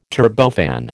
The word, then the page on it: turbofan: American English